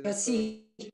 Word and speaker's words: Choisy: El abad de Choisy, o Choisy, no sé cómo se pronuncia. – Choisy. – ¿Cómo? Choisy. – Choisy. Ok.